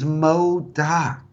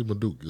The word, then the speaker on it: MODOK: Did I say it wrong? – Yes, his name is Modok. Modok. – Modok. See, Madook is just like French. – Madook is not. Madook sounds like you're about to take a duke.